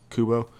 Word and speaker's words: Kubo: Or even, like, with Kubo, Cabo? I always say it wrong. Kubo.